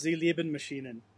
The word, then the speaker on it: Sie lieben Maschinen: Uh, it's pronounced Sie lieben Maschinen. Okay, I just know it's I love machines or something like that.